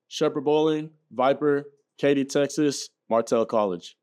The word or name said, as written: Shepherd Bowling